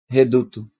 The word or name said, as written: Reduto